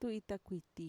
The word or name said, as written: tuitakuiti